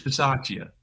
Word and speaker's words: Bisaccia: It's Bisaccia.